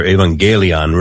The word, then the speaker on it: evangelion: The word evangelium in Greek, or evangelion, really is the Greek pronunciation.